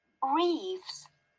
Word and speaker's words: Reeves: A-N-I-K-A. – What's your surname? – Reeves. – Can you spell that? – R-E-E-V-E-S. – R-E-E-V-E-S.